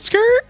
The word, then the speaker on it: Skurt: Skirt. Spelled S-K-U-R-T. – Pronounced Skirt.